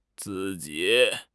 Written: zi4 ji3

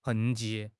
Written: 痕迹

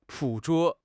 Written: 捕捉

